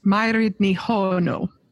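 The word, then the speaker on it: Mairéad Ní Mhaonaigh: Her name is Gaelic, and it's got 100 letters in it, but it's pronounced Mairid Nihono.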